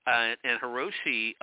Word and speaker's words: Hiroshi: And what I mean by that is when Mike is asked by, is it pronounced Hiroshi? Is that right? – Oh, Hiroshi. Yeah, Hiroshi.